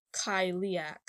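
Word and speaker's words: Cailleach: So, the spelling of the name of the being that I am covering today is C as in cat, A-I-L-L-E-A-C-H, but it's pronounced Kyliak.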